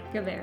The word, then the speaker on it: Guevara: I didn't say that guy's last name right, did I? – No. – No. It's Che... – Che Guevara. – Guevara.